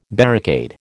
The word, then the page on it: barricade: American English